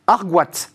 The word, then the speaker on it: Argoat: et vous l'avez appelé l'entraînement Argoat. Est-ce que je prononce bien déjà Exactement. Alors oui, c'est un nom breton hein, qui vient en dualité de Armor, qui est la mer.